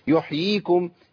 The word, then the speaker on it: يحييكم: اه كتير من الناس يقول ايه ثم يحييكم، يا اخي الله يكرمك خد بالك انت اسقطت ياء كامله، يحييكم يحييكم، ايوه كده لازم تمكن، ما احنا قلنا ده اسمه ايه بقى، مد تمكين تمكين.